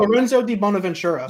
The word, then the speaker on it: Lorenzo di Bonaventura: Um, oh, Lorenzo Di Bonaventura.